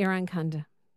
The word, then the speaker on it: Irankunda: Yep, Irankundra? – Nestori Irankunda.